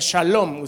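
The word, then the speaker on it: shalom (שלום): Y después, por supuesto, viene la palabra paz, que en el hebreo se pronuncia shalom.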